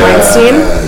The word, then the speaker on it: Weinstein: That's how you say his name, right? Yeah. Weinstein.